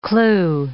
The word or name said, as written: clue